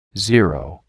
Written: Zero

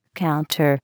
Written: counter

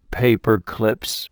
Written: paper clips

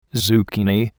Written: zucchini

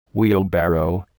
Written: wheelbarrow

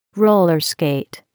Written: roller skate

